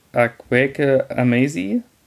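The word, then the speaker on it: Akwaeke Emezi: genau, die Info, das Buch ist auf Deutsch erschienen, im Eichborn Verlag, Akweke Amazi, habe ich den Namen richtig ausgesprochen? – Genau.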